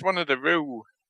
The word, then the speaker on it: Roux: Most of today's children with this name pronounced Roux will spell it Roux. So this is great on the radio, but so R-O-U-X is the name, but most of today's children are pronounced R-O-O. But they will spell it R-U-E, which is the female character in The Hunger Games, something I've never watched.